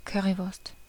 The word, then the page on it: Currywurst: [ˈkœ.ʀi.ˌvʊʁst]